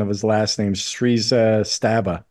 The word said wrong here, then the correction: Srivastava: That could be is Srivastava.